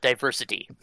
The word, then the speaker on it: Diver City: It's Diver City, but then it's they pronounce it Diversity. – Well, why wouldn't you? – Yeah, which I thought was because like it's confusing because it's supposed to be pronounced all as one word, but sometimes there's a space between diver and city.